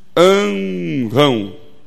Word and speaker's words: Anrão: Tem que pronunciar assim. Anrão.